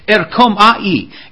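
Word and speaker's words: erkomai (ἔρχομαι): And that word come is so interesting as where it's erkomai, and it's you can spell it English, Deb, because good good luck trying to spell it after I said it. It's e r k h o m a H E E. E R K H O M A H E E. Erkom Ai.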